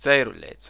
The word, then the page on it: Ceiruleits: Tseiruleits